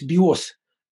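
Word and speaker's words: биос (βιός): Он говорит луку, то есть биос. Биос совпадает с словом биос, только ударение другое жизни.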